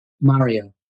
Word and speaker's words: Mario: Mario.